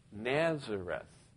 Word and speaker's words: Nazareth: And so there was a whole town of people that wanted to be the branch, and so they lived in the city of Netzareth, which we pronounce in English Nazareth.